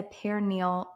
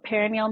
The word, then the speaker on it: perineal: What is a perineal massage, if I'm saying that correctly? – Yes, you are saying that correctly. So perineal massage, the perineum is a bulk of tissue that is between our vagina and our anal canal.